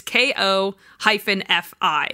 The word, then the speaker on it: Ko-fi: It's K-O-F-I. – I would say Ko-fi. – Ko-fi? That's what I thought too. But then why they say buy me coffee, but it's Ko-fi. Ko-fi. But it looks like Ko-fi.